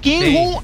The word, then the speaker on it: Kim Jong-un: Así lo dije bien. Kim Jong-un. – Sí. Esa es la que va.